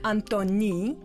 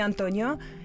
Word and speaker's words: Antoni: На каталонском он Антони, не Антонио, Антони.